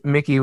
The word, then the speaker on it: Mhikey: It's Mickey with an H in there. M-H-I-K-E-Y. – Mickey. – No, it's still pronounced Mickey. You don't pronounce the H.